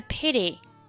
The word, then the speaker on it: pity: What a pity. – What a 连读。 – What a. – What a. – What a pity. – What a pity. – 注意 pity 这个单词，美式发音后面的 t 要被浊化成类似于 d 的发音。